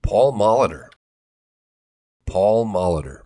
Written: Paul Molitor

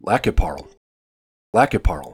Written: Lac qui Parle

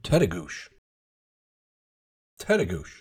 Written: Tettegouche

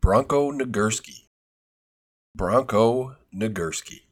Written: Bronko Nagurski

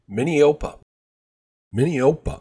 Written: Minneopa

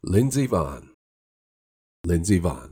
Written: Lindsey Vonn

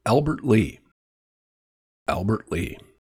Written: Albert Lea

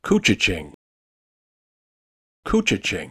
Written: Koochiching

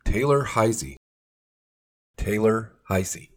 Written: Taylor Heise

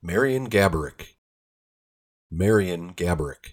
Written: Marian Gaborik

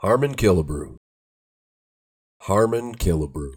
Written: Harmon Killebrew

